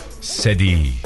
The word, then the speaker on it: Sadie: S-A-D-I-E nasıl okunur bu? – S-A-D-I-E Sedi.